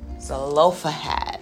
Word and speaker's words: Zelophehad: Zehibahab. Lord, I don't even know how to pronounce it. Zelophehad.